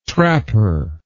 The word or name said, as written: trapper